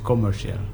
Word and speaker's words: commercial: Because in English pronunciation, sometimes we cannot hear the very hard for the R, yeah. Okay. Jadi commercial. – R-nya agak sedikit tersembunyi.